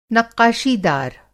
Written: नक़्क़ाशीदार

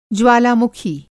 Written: ज्वालामुखी

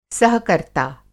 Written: सहकर्ता

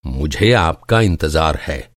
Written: इंतज़ार

